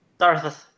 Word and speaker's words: Sarthes: Sarthes, yes. Originally, it was Sarthes, because it was the German pronunciation. – Okay. – But you can't do a thorn in most English sites.